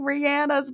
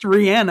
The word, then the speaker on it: Rihanna: But it was funny because, like, yeah, in the caption, it was like, real ones know that her name is pronounced Rihanna mm-hmm. and not Rihanna, but yeah.